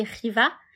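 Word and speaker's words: Khiva: Alors, ça s'écrit Xiva, mais ça se dit Riva.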